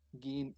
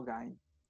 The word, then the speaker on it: Gein: voy a aclarar por qué se pronuncia Gein y no Gain.